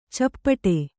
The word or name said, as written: ಚಪ್ಪಟ್ಟೆ